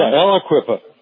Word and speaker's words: Aliquippa: Aliquippa? – Yeah, Aliquippa.